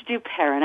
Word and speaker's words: Duperon: Am I saying that right, Duperron? – You know, you're close. It's Duperron. I married a Frenchman.